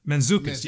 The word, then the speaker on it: Mantzoukas: I know I always say his name wrong. Small part, but I love seeing this guy anytime he pops up in a movie. Jason Man. Is it Mantzoukas? – Oh, Jason Mantzoukas, you know yeah.